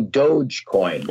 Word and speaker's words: Dogecoin: I saw Elon Musk's name, but Elon, we know, is in cryptocurrency through, what is it, the doggy? How do you say that? Doggy coin? – Oh, Dogecoin.